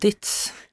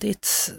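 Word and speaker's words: Dietz: En av dina nära vänner är ju Margot Götz då. – Mm. – Uttalar hennes efternamn rätt nu? – Margot Ditz. – En av dina, Puck Ditz. – En av dina bästa vänner är då Margot Ditz.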